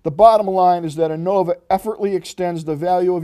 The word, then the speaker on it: Onavo: This is called, I believe it's pronounced Onavo, O-N-A-V-O, Extend.